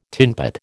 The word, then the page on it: tinpot: American English